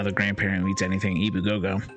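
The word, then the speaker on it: Ebu Gogo: It's just phonetically spelled the same way? – Ibu Gogo. E-B-U space Gogo.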